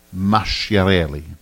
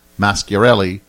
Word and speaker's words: Masciarelli: If a word has a C in it, it is pronounced with a CH, as in Masciarelli. But if it has a CH in it, it is pronounced like a K.